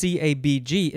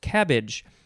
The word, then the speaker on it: CABG: And when I finished recording that episode, my wife said, it's not pronounced CABG, it's actually pronounced cabbage. So in the medical community, if you're in that community, you were probably very angry that I said it wrong. No, but it's they say cabbage,